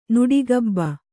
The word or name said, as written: ನುಡಿಗಬ್ಬ